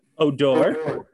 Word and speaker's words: Odor: Am I pronouncing that name correctly? – It's definitely not Rugged Outdoors. – Odor. It is Odor. It looks like rough odor.